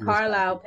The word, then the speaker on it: Carlisle: Okay, it's Carlisle, Pennsylvania, but it's spelled Carlisle. – C-A-R-L-I-S-L-E. Yeah, Carlisle.